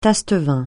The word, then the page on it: taste-vin: francais